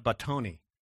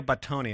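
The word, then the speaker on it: Batoni: And here's one by Pompeo Battini. I hope I said that right. If you're Italian, tell me afterwards how to say that properly. But no, it's Battoni. Pompeo Battoni.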